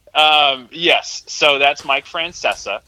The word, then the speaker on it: Francesa: But I was just with someone two weeks ago who was calling him Francesca too, and I'm like, is there some like deep like uh, underground Italian pronunciation that like that I don't know about? But he calls himself Mike Francesa.